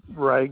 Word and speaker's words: Ray: Okay, it's Ray Gregorius.